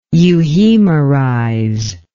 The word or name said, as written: euhemerize